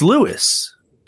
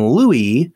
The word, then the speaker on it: Louis: because it's Lewis. And that's how he refers to himself, as Lewis. But if you call him Louis, like Louis Armstrong or Lucky right.